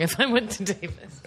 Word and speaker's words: Davis: It rhymes with Mavis. – Davis? I went to Davis? – Yeah, University of California, Davis.